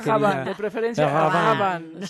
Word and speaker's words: Rabanne: Y sí se pronuncia Rabanne, ¿verdad? – Paco Rabanne. – Ahí está. – Ya se confirmó.